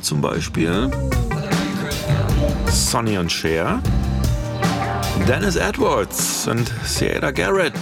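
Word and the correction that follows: Siedah Garrett: Moment, stopp. Zurückgespult. Saida Garrett habe ich neulich gelernt. Ich habe es auch immer falsch ausgesprochen.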